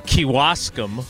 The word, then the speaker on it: Kewaskum: All right, well, let's start with Dan in Kewaskum, both of which I just pronounced correctly. Dan, good morning. How you doing? – Good morning. Absolutely nailed it.